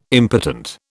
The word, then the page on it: impotent: British English